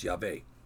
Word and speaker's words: Yahweh: with that I pronounce Yahweh.